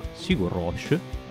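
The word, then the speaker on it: Sigur Rós: E stiamo parlando appunto dell'album di debutto di una band che si chiama Sigur Roche, almeno così la pronuncia che ci dà Wikipedia con,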